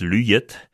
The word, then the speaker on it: luyet: En fait, « luyet » est la prononciation léonarde de « luziet », qui veut dire embrouillé, à ne pas confondre avec le verbe luha, briller, qui se prononce luya en léonais.